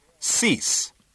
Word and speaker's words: cease: Cease. – Cease. – Cease. – Step 2. Repeating. – Cease. – Cease. – Cease. – Step 3. 让我们同时朗读。1, 2, 3. – Cease. Cease. Cease. – 千万不能读成了 cease，两个都读 s cease。